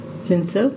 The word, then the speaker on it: Cinsault: The high proportion of Cinsault. Did I pronounce that right? – Cinsault.